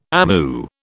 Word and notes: amu: British English

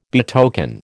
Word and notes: betoken: American English

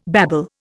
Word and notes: babble: Indian

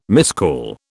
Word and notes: miscall: British English

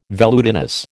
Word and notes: velutinous: American English

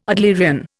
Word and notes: Adlerian: Indian

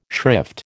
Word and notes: shrift: American English

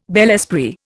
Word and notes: bel esprit: Indian

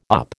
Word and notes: up: American English

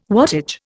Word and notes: wattage: Indian

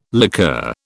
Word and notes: liqueur: British English

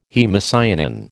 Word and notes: haemocyanin: American English

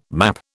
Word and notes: map: British English

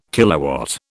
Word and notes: kilowatt: British English

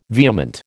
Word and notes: vehement: American English

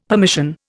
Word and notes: permission: Indian

